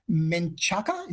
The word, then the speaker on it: Menchaca: There's also another great performance by Mark Menchaca, is how you pronounce it.